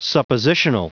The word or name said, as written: suppositional